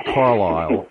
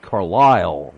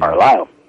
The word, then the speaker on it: Carlisle: They don't pronounce it Carlisle. They pronounce it Carlisle with the accent at the end. – Carlisle.